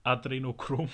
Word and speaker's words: Adrenochrom: Ja, ja, also das, äh, also das Interessante ist, dass dieses, äh, ich kann das nie aussprechen, Adenochrom, wie heißt das nochmal? – Adrenochrom.